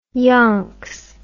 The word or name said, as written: yonks